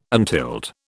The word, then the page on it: untilled: British English